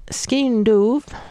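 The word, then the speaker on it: skean dubh: Skeen Doove. I'll spell that for you. S-K-E-A-N, new word, D-U-B-H. I wouldn't bet my life on how I'm pronouncing that.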